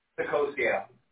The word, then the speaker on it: Saccocio: Sacosia.